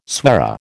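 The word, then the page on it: soirée: American English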